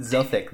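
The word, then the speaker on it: Zothique: I heard somebody pronounce it Zothik the other day. – Zothik? – Like Gothic? – Yeah, like Gothic. – Ooh. – It rhymes with Gothic. Yeah. Weird. – I'm down. – I like Zothik better. – Yeah, me too. Yeah. It's like Mystique.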